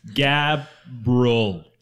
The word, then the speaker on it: Gabriel: Gabriel. Two freaking syllables. Oh my God. – Gabriella.